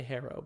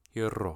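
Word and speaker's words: Herro: You're supposed to roll the R. Hero.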